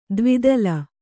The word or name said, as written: ದ್ವಿದಲ